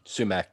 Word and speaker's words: Sumac: How do you say it? Sumac? – Sumac, yep.